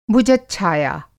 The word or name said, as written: भुजच्छाया